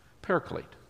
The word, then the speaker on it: paraclete: That's how we'd pronounce it, paraclete.